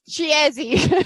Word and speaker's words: Chiesi: Because it's Chiesi. C-H-I-E-S-I.